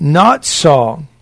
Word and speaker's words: natsal: It's loon natsal, pronounced not saw.